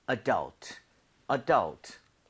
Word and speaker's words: adult: 자, 강세를요. U에다가 찍고 발음을 하면 좋습니다. 자, 이렇게 한번 따라해 보세요. – 네. – Adult, adult.